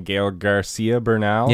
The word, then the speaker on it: Gael García Bernal: I was just saying Gael García Bernal.